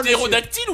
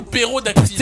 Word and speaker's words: ptérodactyle: Attendez, c'est ptérodactyle ou pérodactyle? – Ptérodactyle. – Vous avez pas dit ptérodactyle. – C'est pété au début. – Il se prononce pas forcément super bien le P.